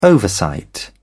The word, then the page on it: oversight: British English